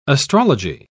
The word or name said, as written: astrology